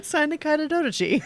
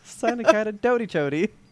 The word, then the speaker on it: synecdoche: Synecdoche. – Synecdoche.